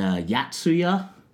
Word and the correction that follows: Yotsuya: Yeah, is it? Yotsuya. – Yotsuya, pardon me, pardon my pronunciation.